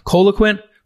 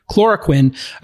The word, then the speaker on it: chloroquine: I mean, I, have heard uh, the coloquin, am I pronouncing correctly? Coloquin chloroquine.